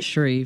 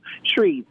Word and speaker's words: Shreve: His opponent, Shriver, I want to pronounce his name right. – Shreve. – He had said, yeah, Shreve, that's right. One syllable, not two.